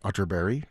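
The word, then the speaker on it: Atterberg: It's spelled Otterberg, but pronounced Otterberry, just in case you were Googling.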